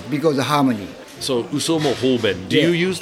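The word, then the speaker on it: Uso mo hoben (嘘も方便): What comes to mind is a very Japanese phrase, uso mo hoben. – Okay. – Uso mo hoben. Am yeah. I saying I'm saying that correctly, yeah, right? That's correct. That's correct.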